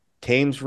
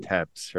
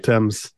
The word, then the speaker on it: Thames: The Thames River, ran across there. I mispronounced that. Thames, right? – Thames, yeah.